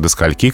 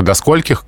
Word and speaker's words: скольких: Но при этом э, в живой речи массово распространено до скольки, к скольки и так далее. Без «х» на конце и с таким ударением. И все-таки нормой это не является. Так что до скольких, к скольким, со скольких и так далее.